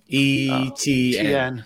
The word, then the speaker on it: Etienne: Trevor Etienne and Etienne.